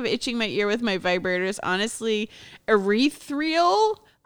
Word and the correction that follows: ethereal: I can't pronounce shit. Ethereal. What is it? – Yeah, ethereal.